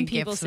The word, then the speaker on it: GIF: It is literally GIFs with the G. – Okay, well, we'll argue about that another day. – It's not like Jiffy peanut butter. It's GIF. G.